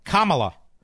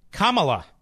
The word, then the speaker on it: Kamala: How do you pronounce Harris's first name? Kamala. Kamala. It was Kamala. Now she's running for president. It's Kamala. And so does Kamala Harris.